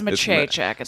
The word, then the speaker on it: Maciejczyk: It's Maciejczyk. It's spell it. – M-A-C-I-E-J-C-Z-Y-K is my real Polak.